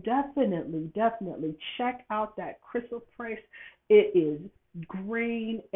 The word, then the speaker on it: chrysoprase: And the stone that you can work with that goes along that Ascended Master Hilarion has you working with is the chrysoprase, That's how you pronounce it, chrysoprase.